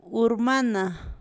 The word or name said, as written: Urmana